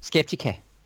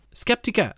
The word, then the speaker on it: Skeptica: But how would it go with Skeptica? – Skeptica. – Skeptica. Ah, okay. So I did make a mistake of pronouncing it.